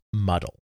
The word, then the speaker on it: MDL: which was normally pronounced Muddle.